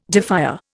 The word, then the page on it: defier: Indian